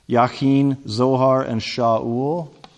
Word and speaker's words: Shaul: Shaul sounds like a weird Middle Eastern name, doesn't it? But it's the name Saul. And this kind of gets into the pronunciation thing and how it's a little bit problematic, because everybody knows the king that preceded David, right? We call him Saul. We don't call him Shaul usually, but the name is exactly the same in Hebrew.